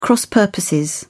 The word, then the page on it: cross-purposes: British English